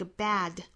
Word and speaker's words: bad: When something bad happens, 这个 bad, 美音的话呢是左右的嘴角裂开，口型压的比英音呢要更低。